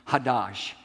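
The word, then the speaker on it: chadash: Now, if you want to write it down in your notes, here's the English way it's spelled. C-H-A-D-A-S-H. C-H-A-D-A-S-H, but it's pronounced hadash.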